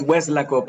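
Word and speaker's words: Weslaco: Nacido en Hueslaco, lo dije bien. es Hueslaco, pero está bien.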